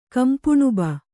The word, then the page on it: ಕಂಪುಣುಬ: kannada